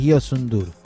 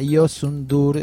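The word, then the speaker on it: Youssou N'Dour: El que nos vamos a ocupar hoy se llama Yosundur, es un nombre bastante difícil, dice Pablo acá, de pronunciar. Se escribe Yosou Ndour.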